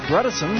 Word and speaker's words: Bredesen: We have with us Governor Phil Bredesen. Did I say it right? – You said it. – Hey. – State of Tennessee. – Governor Philip Bredesen, governor of the state of Tennessee.